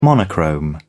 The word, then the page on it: monochrome: British English